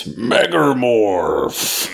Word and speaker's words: Megamorph: Say it properly. It's Megamorph.